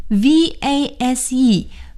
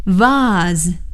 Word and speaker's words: vase: 有的时候朋友们给我们买了鲜花，我们要放在这个花瓶里，花瓶 v a s e vase vase。或者也可以念成 vase, vase, vase, vase, 都是花瓶的意思。